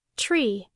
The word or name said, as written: tree